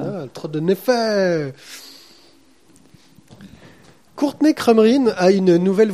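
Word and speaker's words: Naifeh: Courtney Crumrin, le tome 5, Courtney Crumrin et l'apprenti sorcière de Ted Neff. Je ne sais pas comment du tout on peut prononcer ça. – Neff. – Voilà, ça me fait le Neff.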